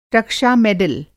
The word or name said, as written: रक्षा-मेडल